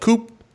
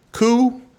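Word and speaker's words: Coup: Another game we play at our group of board game friends is a game called Coop. Coo? Not Coop. – Coo. – P is silent.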